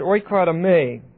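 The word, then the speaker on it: oikodome (οἰκοδομή): Now, this word building is the word oikodome, O-I-K-O-D-O-M-E, long E. Now, oikotome means two things.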